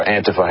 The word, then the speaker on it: Antifa: and they were talking about Antifa or Antifa, however you want to pronounce it, calling them the alt-left.